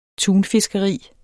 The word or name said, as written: tunfiskeri